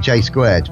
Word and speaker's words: JSQRD: That was, I think, now, it's spelled J-S-Q-R-D. Just good. I'm calling it J-squared.